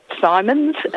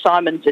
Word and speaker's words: Simons: Sure, it's at Margaret Simons, and Simons is spelled S-I-M-O-N-S, like the boy's name with an S on the end, Margaret Simons.